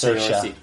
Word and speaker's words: Saoirse: It's Saoirse.